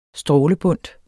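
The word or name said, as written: strålebundt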